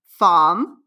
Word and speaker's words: FAIM: Well, first, it's pronounced FAIM. – FAIM. – Which is a French word.